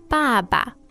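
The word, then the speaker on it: Baba (爸爸): Baba.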